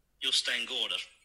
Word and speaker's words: Jostein Gaarder: Unser Autor heißt Justin Gorder.